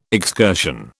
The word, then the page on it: excursion: British English